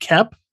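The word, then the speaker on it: Koepp: Okay, so Kimmy, so directed by Steven Soderbergh, written by David, is it Koep or Kep? I'm not sure how to pronounce his last name. – I've always heard it Kep. – Kep, okay.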